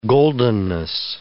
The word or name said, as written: goldenness